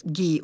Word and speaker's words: Gee: It's G-E-E, so I think it's Gee or G.